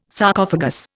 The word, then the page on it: Sarcophagus: Indian